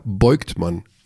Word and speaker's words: Vogtmann: Vogtmann heißt ja Beugtmann in Vitoria, weil die das V wie B aussprechen.